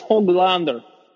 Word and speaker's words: Höglander: I'm excited about the potential of Höglander playing the second line. – Ooh, okay, yeah. Nils Höglander.